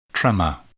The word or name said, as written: tremor